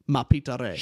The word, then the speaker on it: Mapidéré: Mapidéré. Shit. okay, with Mapidéré. So he's Imagine he comes down French. Here. – That's what. Just think French. It, think French, and you'll say it right.